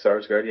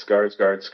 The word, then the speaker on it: Sarsgaard: I think it's Sarsgaard, yeah. I don't think it's Skarsgård. Skarsgård's the other guys.